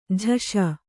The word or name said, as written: ಜೈಸು